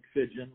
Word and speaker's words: Fidgeon: Yeah, I typically direct people to my LinkedIn at Mike Fidgeon. My last name is spelled F-I-D-G-E-O-N.